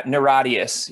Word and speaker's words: Niratias: So it's Niratias. Is that how you pronounce it? I know it's an acronym. – Yeah, yeah, Niratias. Yeah, is how, okay. – Yeah, I mean that's how we say it.